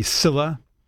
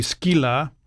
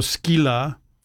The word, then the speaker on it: Scylla: Yeah, and before I read this, I have to say, so someone in the YouTube chat was saying that the pronunciation should be Scylla. And it is true that I think that is a historical Yes. – English pronunciation. But it's worth noting that the actual Greek pronunciation would be Skyla, because it's sigma kappa epsilon lambda lambda alpha. So Skyla.